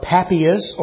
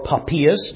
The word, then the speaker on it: Papias: And it comes from a man by the name of Papias, or Papias, depending on how you say his name.